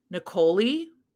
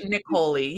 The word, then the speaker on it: Niccoli: So this is Given to the Ghoul by, I have a pronunciation here, Desiree, Niccoli. Yeah. Because it rhymes with cannoli. – That's Desiree Niccoli.